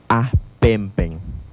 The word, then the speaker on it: ahpempen: Escribió mariposa, y en la lengua yocotán se pronuncia ahpempen.